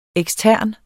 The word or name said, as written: ekstern